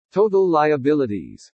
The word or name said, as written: TOTAL LIABILITIES